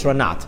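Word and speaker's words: Rananat: Now, if you say the words Resh Nun Tes, you pronounce it Ranat.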